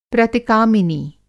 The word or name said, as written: प्रतिकामिनी